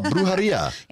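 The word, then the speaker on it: brujería: Oh, brujeria. – Yeah. – Excellent. Ooh, that was a really good pronunciation.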